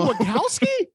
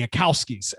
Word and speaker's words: Wachowskis: And that, like, I feel like that's the complaint is that, like, you know, when you're an artist, when you're the Wachowskis, I always say it wrong. – It's not that hard. – Well, I've been saying it like that for 20 years. So like, you've been saying Wachowski.